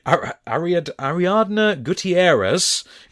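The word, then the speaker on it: Ariadna Gutiérrez: So, the very, very brief Miss Universe, the former Miss Columbia, Ariadna Gutierrez, you know, the one who... Never mind. – No, I'm saying well done to you for your pronunciation.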